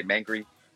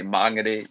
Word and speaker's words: Māngere: So I think learning about that, you know, getting my pronunciation right, you know, sometimes I always want to say Mangere, but I know that I have to say Māngere, you know, now.